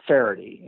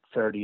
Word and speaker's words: Feherty: I don't play at all, but I make I produce a show for NBC's golf channel called Faherty, and Faherty is spelled Feherty.